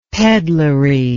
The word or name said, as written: peddlery